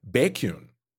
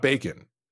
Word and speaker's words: Baekhyun: With Ezreal, we have, and I'm sorry if I butcher this, Baekhyun. Yep. Not Bacon, even though I want to say it.